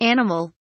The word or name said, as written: animal